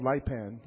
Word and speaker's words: Lipan: It's called Lipan. Well, they pronounce it Lipan.